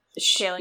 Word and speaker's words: Kaling: There's this line where Mindy Kaling, or Kaling, I can't pronounce her last name. Kaling. Yeah, thank you. I had it right the first time.